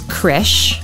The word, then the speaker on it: Krzych: Yes, my last name is Krisch. – Krisch. – Spelled K-R-Z-Y-C-H. – Oh, shoot. K-R-Z-Y? – Yep. – S-C-H, did you say? – K-R-Z-Y-C-H.